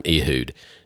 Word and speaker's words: Ehud: All right, so thing I want to talk about Ehud, and we're going to call him, the reason I call him Ehud is so it rhymes with dude, because I call him Ehud, the left-handed dude, you know.